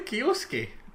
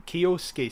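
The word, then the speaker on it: Kyosuke: Kioske. – Kioske. So the S-U-K-E in Japanese is pronounced ske. – Like Shunsuke. Shunsuke. – Yeah. So that's how you remember it.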